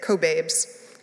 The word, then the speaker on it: Kobabe: And yet, this week, the minister's book group read Maya Kobabe's, I have to work to repronounce her name because I have been mispronouncing it, Kobabe's book, Gender Queer.